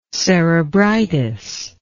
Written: cerebritis

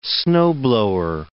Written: snowblower

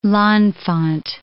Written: L'Enfant

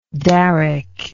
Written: daric

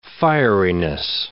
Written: fieriness

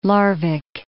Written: Larvik

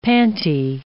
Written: panty

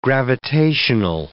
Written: gravitational

